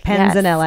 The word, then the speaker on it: panzanella: Tomato, raw beet, and strawberry panzella. – Panzanella. Yes. – I lost a syllable in there.